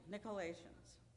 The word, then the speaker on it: Nicolaitans: And I know I say this wrong every time. Nicolaitans. Is that how we say it? Thank you, Nicolaitans.